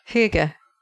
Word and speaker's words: hygge: And, you know, the other thing super, super important here for the Nordic lifestyle buffs out there, you know, you got Hygge. Is that how you pronounce that? Do we know? – Yeah, Hygge. – One more time. – Hygge. More or less.